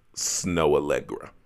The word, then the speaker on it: Snoh Aalegra: But one artist that I think you should listen to goes by the name of Snow Allegra. She is like Irene. – A L L E. – That Snow, it's spelled S N O H A A L E G R A.